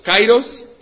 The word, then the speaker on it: kairos: Se escribe K-A-I-R-O-S, kairos, como el pueblo de Cairo, ¿no?, de Egipto.